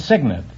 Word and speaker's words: cygnet: No, it's Cygnet. It's spelled C-Y-G-N-E-T, but it's pronounced Cygnet.